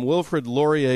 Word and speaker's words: Wilfrid Laurier: So researchers from Wilfrid Laurier University.